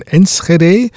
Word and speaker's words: Enschede: aber damals war das nur Enschede oder auf Holländisch ausgesprochen, Niederländisch richtig ausgesprochen, Enschede äh, in Holland, in Niederlanden, korrekterweise.